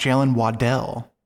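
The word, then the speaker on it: Jaylen Waddle: He announced his name is pronounced Jalen Waddell.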